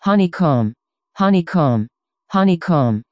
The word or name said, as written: honeycomb